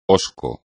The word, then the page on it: hosco: espagnol